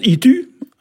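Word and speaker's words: ed du: Alors, la farine de blé noir, alors, le blé noir, ici, on l'appelle, alors, c'est édu, hein, ici, on prononce itu, hein, et dans certaines régions, c'est guinisdu, enfin, qui est même...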